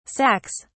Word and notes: sacks: British English

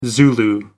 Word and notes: zulu: American English

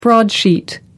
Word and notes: broadsheet: American English

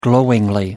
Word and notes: glowingly: British English

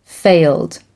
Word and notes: failed: American English

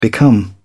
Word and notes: become: British English

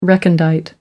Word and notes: recondite: American English

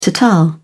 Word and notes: ta ta: British English

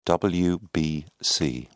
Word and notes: wbc: British English

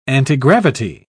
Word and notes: antigravity: British English

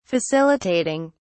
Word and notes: facilitating: British English

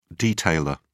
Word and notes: detailer: British English

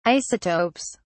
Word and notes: isotopes: British English

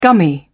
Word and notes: gummy: British English

